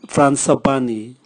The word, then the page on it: fransapani: Raga